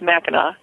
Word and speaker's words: Mackinac: But it was always pronounced Mackinac.